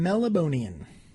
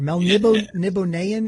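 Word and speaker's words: Melnibonéan: Cthulhu and Melibonian. Or Melnibonean, yeah. as some people pronounce it. That's how it's pronounced yeah. in the audiobook.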